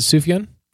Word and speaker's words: Sufjan: It's a soft J. – On his commercial. Is it Sufjan? – Yeah.